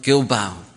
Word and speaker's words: Gilbow: I've recently come across inspiration with Simon Gilbow. That's how he pronounced the second name.